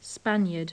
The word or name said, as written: spaniard